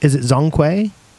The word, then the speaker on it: Zhongkui: Is it Zhongkui? – Zhongkui. – Zhongkui. – We never know how these Chinese gods are pronounced.